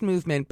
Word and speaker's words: MVMT: It's pronounced Movement, but they're chic, so they spell it MVMT, which, you know, I love a chic abbreviation.